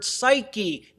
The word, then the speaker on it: psyche: The word for soul in the Greek is the word psyche. This also has a silent P at the beginning. It is spelled P-S-Y-C-H-E.